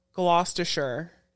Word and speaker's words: Gloucestershire: That's in Gloucestershire.